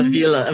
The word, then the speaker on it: Avila: Avila.